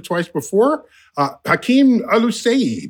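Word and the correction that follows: Oluseyi: Did I pronounce that right, Hakeem? – Close. Yeah. It's a O and a sh. Olusheyi. Think Olusheyi. Olusheyi. But it's Olusheyi. Yeah.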